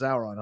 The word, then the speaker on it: Sauron: And uh, I pronounced that correctly because someone pointed out I, I wasn't going to take you. I, I, was taking you seriously until you mispronounced Sauron.